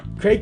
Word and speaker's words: Kreg: My name is Craig Kiegler. Craig Kiegler with a K. That's spelled K-R-E-G.